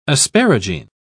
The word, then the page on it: asparagine: British English